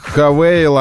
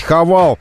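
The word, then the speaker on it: Haval: Раньше мы знали их как Ховеры, сейчас мы их знаем как Хавейл. Они именно так просят себя называть. Хавал. Мы, ну так, на наш манер произношения их уже именуем Хавал. Они хотят, чтобы они были Хавейл, но от этого смысл никак не изменяется.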